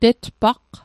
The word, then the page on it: tetpaqq: Mi'gmaq